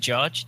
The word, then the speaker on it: Jarge: It's been Jarge. It's like a hard J because my real name is Josh.